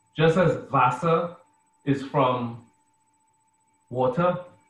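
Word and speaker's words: Wasser: The word Vasa is spelled with a V, with a W, sorry. In German, you spell a word with a W, but it's pronounced as a V.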